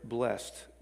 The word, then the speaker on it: blessed: So when it's used as a verb, it's one, creates action, right? Verb. It's used as one syllable, as in blessed, B-L-E-S-T, blessed.